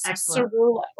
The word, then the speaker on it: Ciruolo: It is Ciruolo.